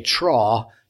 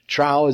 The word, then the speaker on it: trough: A sword in one hand to protect from enemies that were threatening from outside, and a trough to lay bricks in the other hand. Trough, is that how you pronounce it? Thank you. I almost said Jeremiah again. I got trough.